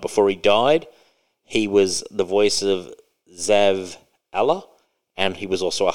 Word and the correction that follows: Zavala: It's pronounced Zavala. – Zavala.